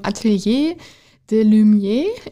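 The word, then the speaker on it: Atelier des Lumières: Ähm, und zwar äh, von Van Gogh die Sternennacht im Atelier de Lumière.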